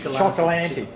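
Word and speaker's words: Cioccolanti: if you watch Steve Chicolanti. Chicolanti. How do you say his last name? Chocolanti.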